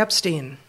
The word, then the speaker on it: Epstein: Would you talk about Jeffrey Epstein? Steen? Did I say that right? – Yeah.